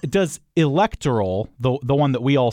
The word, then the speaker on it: electoral: It's the antepenult. It's the first syllable, going backwards. Ul is short, ter is short, so therefore it should be technically electoral. – Okay. – That should be technically the correct pronunciation.